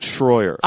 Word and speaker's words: Schroyer: I've always pronounced it Schroyer. – I know, but to me, I hear Troy.